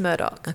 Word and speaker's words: Murdaugh: And by the way, Murdoch is spelled Murdaugh. – Oh, but okay. pronounced Murdoch.